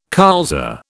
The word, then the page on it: colza: British English